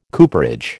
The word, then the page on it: cooperage: American English